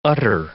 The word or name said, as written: utter